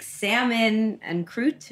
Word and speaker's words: salmon en croute: fantastic salmon en croute.